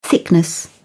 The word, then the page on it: thickness: British English